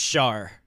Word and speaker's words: Char: It's pronounced Char. – Char. It was Char. But she's kind of like... – It's French.